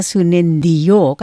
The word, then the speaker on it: Sunendio: Ini ejaannya memang ejaan lama kan, Bapak ya? Jadi saya menyebutkannya Risa Yosia Sunendio. Kan begitu kan, Bapak? – Betul sekali, Sunendio.